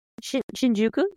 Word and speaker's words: Shinjuku: Shinjuku, yes.